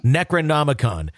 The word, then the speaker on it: Necronomicon: Necronomicon.